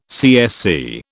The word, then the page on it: CSC: British English